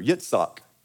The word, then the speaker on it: Yitzhak: Yitzhak is how you say that in Hebrew.